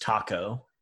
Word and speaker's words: Tako: Okay, well, I think that the the next step for you then is to go to I think it's pronounced taco, T A K O Korean barbecue, uh, and it's on Alhambra.